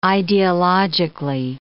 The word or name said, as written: ideologically